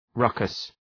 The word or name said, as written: ruckus